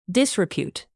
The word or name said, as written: disrepute